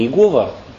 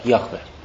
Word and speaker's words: Яхве: Вот, вы знаете, да, что есть имя Иегова, которое на самом деле неправильно произносится, потому что изначально произношение этого имени в священном еврейском языке звучало как Яхве, то есть сущий.